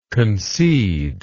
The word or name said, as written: conceder